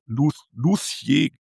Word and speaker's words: Luthier: Wir haben Tal der Gnobe, Landgut, wir sprechen über Tech-Team, Luthier. Ja, wird das eigentlich ausgesprochen? Der Geigenbauer, der Instrumentenbauer, Lucia, ja, okay, das ist gar nicht so einfach.